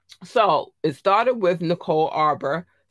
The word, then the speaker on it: Arbor: But let's get into this Cardi B and Nicole Amber. Or at Arbor, let me say her name correctly, Arbor, situation.